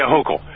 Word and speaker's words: Hochul: Hochul? – Yes. – Yeah, Hochul.